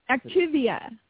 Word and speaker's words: Activia: How do you say that? Is it Activia? How do you say that, anyway? – Activia.